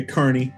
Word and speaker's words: Kearney: Kearney.